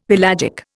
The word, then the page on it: pelagic: Indian